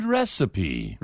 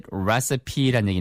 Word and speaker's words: recipe: It's interesting to work out what makes a good recipe. – 맞습니다. It is interesting, 흥미로운 거죠. 뭐 하는 거죠? To work out, 궁리내는 것이 뭘요? What makes a good recipe라는 얘기니까 R-E-C-I-P-E. 발음 주의하세요. 이거 recipe 아니에요. Recipe.